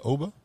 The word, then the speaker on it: Oba: I guess that's how you pronounce that, Oba? – Yeah, you got it.